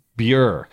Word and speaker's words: Bure: Is it Bure? How do you pronounce? – Bure.